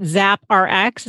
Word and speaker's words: ZapRx: So is it ZapRx? Is that how you pronounce it? – Yeah. Yeah.